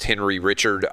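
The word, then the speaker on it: Henri Richard: And if his name is pronounced Henry Richard, I apologize. – No, you hit it. You nailed it.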